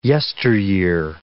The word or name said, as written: yesteryear